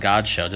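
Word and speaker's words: Godchaux: I'm out at Dolphins camp today and getting a chance to talk to Devon Godshow. Did I pronounce that correctly? – Yes, sir. Yes, you did.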